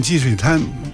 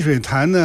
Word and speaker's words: 积水潭: 们在那个沙盘上就看到了我们北京的这个运河水系啊，当时也有这个积水潭的这个位置，但是刚才蔡帆老师说，这个应该叫积水滩啊，为什么叫滩呢？ – 他这个名字吧，这个北京人现在就这么念，就念积水滩。你看是如果是念成积水潭呢，你就不是北京人，这是一个说法。